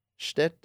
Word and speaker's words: shtick: Like that is like the whole NHL stick. That like shtick. Did I say that right? – Shtick. There you go.